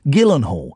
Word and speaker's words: Gyllenhaal: And I respect her so much that I'm saying the hard G. It's a hard G. Gyllenhaal.